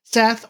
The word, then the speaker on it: Set: But yeah, Seth is the douchebag of that story. – Seth. Sorry, excuse me. – Seth or Set, depending on how you pronounce it.